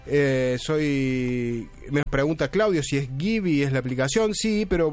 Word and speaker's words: Gibi: Se escribe G de Guillermo, I de Indio, B corta de Víctor, Y de Yankee. Gibi se pronuncia, pero se escribe así, Gibi.